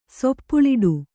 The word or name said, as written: ಸೊಪ್ಪುಳಿಡು